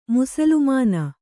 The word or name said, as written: ಮುಸುಲುಮಾನ